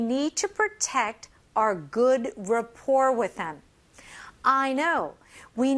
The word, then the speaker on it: rapport: Our good, 여기서는 rapport로 하지만 rapport. 이게 French에 이렇게 영향이 있어서 rapport가 아니라 rapport. T 발음이 없습니다.